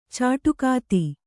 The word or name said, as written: ಚಿಟುಕೊತ್ತು